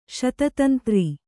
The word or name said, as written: ಶತತಂತ್ರಿ